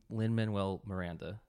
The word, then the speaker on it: Lin-Manuel Miranda: Lin-Manuel Miranda.